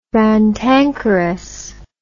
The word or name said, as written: rantankerous